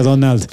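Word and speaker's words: Domhnall: Se escribe como Hald. Sí, se escribe Dom Hald, pero ya, ya, ya salió en cámaras diciendo, se, pronuncia Donald.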